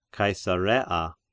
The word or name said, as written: Caesarea